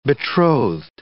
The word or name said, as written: betrothed